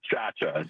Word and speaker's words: Chacha: It's Antonio Chacha, just like the dance.